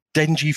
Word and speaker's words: dengue: Now, basically, Sanofi Pasteur's, it was basically for the dengue flu. Dengue fever. Yeah, right? That's the way you pronounce it, yeah.